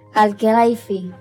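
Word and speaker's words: Al-Khelaifi: Al Khelaifi.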